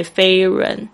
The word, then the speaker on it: Fahrenheit: H E I T，你会发现这 H 没有发音，中间这个 H F A H 这个声音没有发音，直接 Fahrenheit，呃，后面这个 H 它要发音。